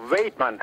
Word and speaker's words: Weidman: The show follows Dutch-American veterinarian Dr. Pohl, his family and employees at his practice in rural, is it Weidman, Michigan? – No, Weidman. It's pronounced Weidman? A little bit different than what you normally say.